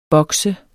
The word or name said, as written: boks